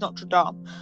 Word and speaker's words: Notre Dame: One thing I did love how they made Notre Dame, and that's actually how you pronounce it, Notre Dame.